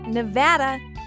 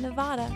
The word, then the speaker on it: Nevada: And reminder that it's pronounced Nevada, not Nevada.